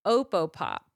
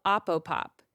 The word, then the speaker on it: Opopop: And he said, have you heard of this company called Opopop? Or maybe it's called Opopop. It's spelled O-P-O-P-O-P. – Ooh. – It's so fun to look at this O-P-O-P.